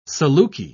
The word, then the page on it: saluki: British English